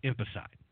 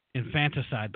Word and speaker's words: infanticide: I guess I should pronounce it correctly. See, it's actually infanticide, but I think if you just say emphasize, if you emphasize the word infant, I think it drives the point home.